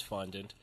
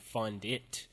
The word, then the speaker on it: fondant: But when I saw how she had spelled the word fondant, F-O-N-D-A-N-T, I realized that I had been saying it wrong throughout the entire episode. I was saying fondit, F-O-N-D-I-T, it's not even a word.